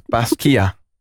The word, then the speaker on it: Basquiat: Oh, Tasha says, you pronounce Basquiat, how I was saying it wrong. It's pronounced Basquiat.